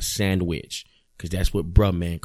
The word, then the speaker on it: Bruh Man: Not Brother Man, but Bruh Man. You know, you got to say it like that.